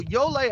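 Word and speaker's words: Ioele: Well, Yole, I had to hear the audible version of it because I had no idea on reading it how to pronounce it.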